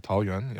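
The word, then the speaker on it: Taoyuan: So anyways, Dwight is playing with the Tao Yuan Leopards. Did I pronounce that right? – Man. – Can I consult you on this? – Yeah, Tao Yuan.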